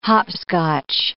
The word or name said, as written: hopscotch